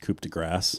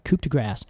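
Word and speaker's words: coup de grâce: Way longer, and the real coup de grace to tell them apart is the cone. – I don't mean to embarrass you, but it's pronounced coupe de grâce. – Oh, I'm sorry, coupe de grâce.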